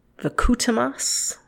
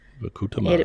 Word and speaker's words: Vkhutemas: All right, next we're going to go to um, basically constructivism school, and okay, I'm not sure how to say this word. It's Vakutemas. Let's let's try that. It's spelled V K H U T E M A S. – Vakutemas.